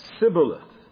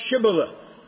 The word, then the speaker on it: Shibboleth: Then said they unto him, Say now, Shibboleth. And he said, Sibboleth. For he could not free him to pronounce it right.